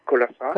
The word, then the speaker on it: Colonsay: Et voilà, c'est comme ça que je pense que j'ai découvert, par exemple, Colossa, ça s'écrit C-O-L-O-N-S-A-Y, oui, oui. – Mais les habitants Colense. De l'île prononcent Colossa.